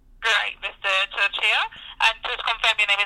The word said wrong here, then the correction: Torchia: Yeah, it's actually pronounced Torsha, but yeah. – Torsha. I'm sorry about that. – You know what? That's okay. It's hard to pronounce my last name. I understand that. – Okay, then. So it's Torsha, right? – It's Torsha.